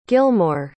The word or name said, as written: gilmour